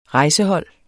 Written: rejsehold

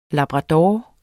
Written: labrador

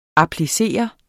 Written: applicere

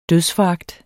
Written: dødsforagt

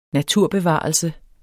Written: naturbevarelse

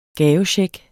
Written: gavecheck

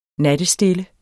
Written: nattestille

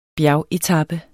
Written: bjergetape

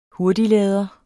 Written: hurtiglader

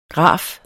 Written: graf

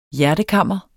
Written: hjertekammer

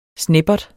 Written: sneppert

